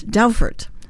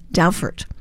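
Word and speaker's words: Dowfart: It's Doufert, spelled D as in dog, D-O-W, F as in Frank, A-R-T, D-O-W, F-A-R-T, pronounced Doufert.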